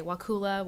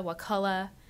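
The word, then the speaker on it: Wakulla: It is in the language, and actually I have an essay about Wakula Springs and about the pronunciation of Wakula, which I can say Wakula, Wakula. My father says Wakula. My grandmother's pronunciation I can't even replicate, and it's even more amazing.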